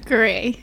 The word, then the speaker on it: Garay: Garay.